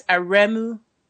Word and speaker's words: Aremu: What is Oremon in? Or I don't know how you pronounce it. – Aremu? – Yes. Say it again. – That's Aremu.